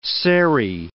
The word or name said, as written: serry